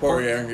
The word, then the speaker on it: Poirier: Poirier, yeah.